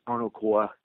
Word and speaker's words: Arno Corps: And Arno Corps, the PS is silent right there. So it's Arno Corps, much like the Marine Corps or the Peace Corps.